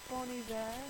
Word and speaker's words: Bon Iver: Don't do it. Don't say it like that. – Bon Iver.